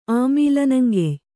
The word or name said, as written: ಆಮೀಲನಂಗೆಯ್